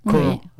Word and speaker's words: Kaur: Kaur, hein. – Alors que ça s'écrit K-A-U-R, mais oui, elle a un nom prédestiné, donc elle chante beaucoup, pour, euh, notamment pour le Kundalini.